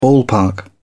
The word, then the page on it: ballpark: British English